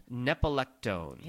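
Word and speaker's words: nepetalactone: It actually has a constituent called nepalectone.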